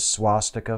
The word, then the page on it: swastika: American English